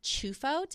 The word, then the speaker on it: Ciuffo: So the real pronunciation is Chufo, but for some reason my family said Chifo because I don't know why, but it's spelled C-I-U-F-F-O. So the correct pronunciation, and in Italy they would say Chufo.